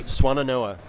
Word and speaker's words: Swannanoa: Your experience includes the Swannona, if I'm saying that correctly. – Swannanoa.